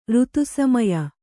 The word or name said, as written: ಋತುಸಮಯ